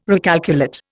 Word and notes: recalculate: Indian

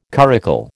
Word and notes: curricle: American English

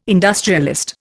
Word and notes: industrialist: Indian

